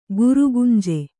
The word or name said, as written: ಗುರುಗಂಜಿ